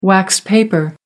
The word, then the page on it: wax paper: British English